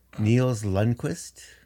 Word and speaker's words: Lundkvist: Oh, I'm going to butcher this name. Nils Lundkvist. Bang on. Ranger. – Sorry. – Bang on. Yep.